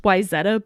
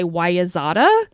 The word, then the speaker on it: Wayzata: Like, the original 90210, the Walshes came from Wayzata, Minnesota, which it's pronounced Wayzata, but in the show they say Wayazata. Because that's how it's spelled.